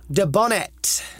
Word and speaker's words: Dubonnet: And then we have a bottle of Dubonnet. – Dubonnet. – Dubonnet. It's spelled French. D-U-B-O... – Double N. – Double N-E-T.